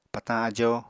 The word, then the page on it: patañ ajo: Apatani